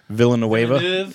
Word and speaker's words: Villeneuve: I'm gonna butcher his name, but the director's name is Denis Villeneuve. Villeneuve. French it up a little bit.